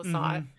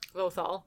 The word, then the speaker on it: Lothal: Lasat. – Lothal.